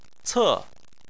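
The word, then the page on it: ce: tone 4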